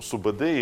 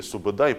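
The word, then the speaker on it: Субэдэй: Ну, Субадей или Субадай, по-разному имя его пишется или произносится.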